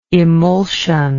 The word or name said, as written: emulsive